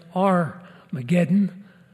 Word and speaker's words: Armageddon: And verse 16, they gather them to the place which in Hebrew is called Har-Mageddon. We usually carry it over and pronounce it Armageddon, but it means the Mount or Hill of Megiddo.